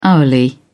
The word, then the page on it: hourly: British English